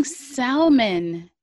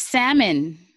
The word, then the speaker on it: salmon: Shout out to the people who pronounce the L in salmon. – Salmon? – Yo. – I've heard that. – Salmon is incorrect, guys. Stop saying salmon. It's salmon.